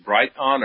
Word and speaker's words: Brighteon: I didn't know whether it was Brighteon or you know, I didn't know how to pronounce it. – It's like two words, bright eon, but I just say bright eon.